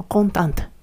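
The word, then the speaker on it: contente: 女性相手の疑問文でも、やはりこのコンタントという発音になりますので、注意してくださいね。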